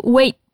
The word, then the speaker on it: wait: Repara que a gente escreve wait, mas acaba falando wait sem pronunciar o T no final.